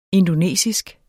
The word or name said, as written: indonesisk